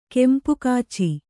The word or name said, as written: ಕೆಂಪುಕಾಚಿ